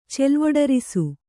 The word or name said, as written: ಚೆಲ್ವೊಡರಿಸು